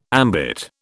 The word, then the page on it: ambit: British English